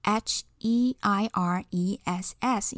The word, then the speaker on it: heiress: 那么女继承人的话，要在后面加上代表女性的字尾 e s s，所以整个单字的拼法是 h e i r e s s，要念成 heiress，heiress。